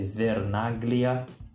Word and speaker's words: Vernaglia: Eu falo Vernaglia, que é a pronúncia, né, do meu sobrenome, mas se escreve Vernaglia, com G-L-I-A no fim.